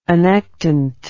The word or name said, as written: annectent